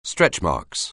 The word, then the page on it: stretchmarks: anglais